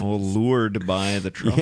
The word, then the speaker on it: leeward: Well, in sailing, there's something called lured as well, which... But it's spelled L-E-E-W-A-R-D, leeward. – Oh. – But sailors say leeward.